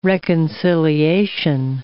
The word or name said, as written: reconciliation